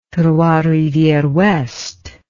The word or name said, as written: trois rivieres ouest